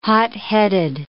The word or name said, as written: hotheaded